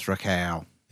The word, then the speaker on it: Rakau: They're from New Zealand, so it's Rakau.